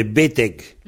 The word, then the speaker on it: betek: Donc, B-T-E-K, B-E-T-E-K, un petit G après, ça indique la prononciation. Ou bien B-E-T-E-G. Il y a les deux façons d'écrire, puisque tout le monde n'est pas d'accord.